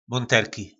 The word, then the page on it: Monterki: po našimu